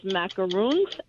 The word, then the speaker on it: macaroons: Well, there's two different kinds. There's macaroons and macarons. They're two totally different things.